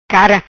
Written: Cara

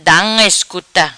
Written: Da Unga Iscuta

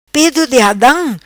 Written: Pedo Di Adam

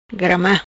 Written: Grama

